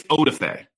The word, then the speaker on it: Odafe: now Adolfo? How do you say that? Adolfo Owe? – I think it's Odafe.